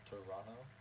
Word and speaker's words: Toronto: People say Toronto, but that doesn't sound good to me. And I've heard actually Canadians say that's not how you pronounce it. It's Toronto, because the second T is apparently silent.